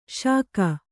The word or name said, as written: ಷಕ್ಕು